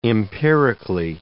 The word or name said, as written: empirically